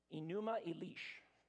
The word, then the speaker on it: Enuma Elish: Enuma Elish, the Enuma Elish.